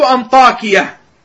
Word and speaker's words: أنطاكية: أحمد بن إبراهيم بن فيل الأسدي، أبو الحسن البالسي، نزيل أنطاكية، والد القاضي أبي طاهر. أحمد بن إبراهيم بن فيل الأسدي، أبو الحسن البالسي. البالسي منسوب إلى بالس، مدينة قريبة من حلب في بلاد الشام. نزيل أنطاكية بتخفيف الياء،